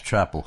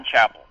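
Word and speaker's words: Chapell: It's Chapel, correct? Not Chappell? – That's right, yeah, Brian Chapel.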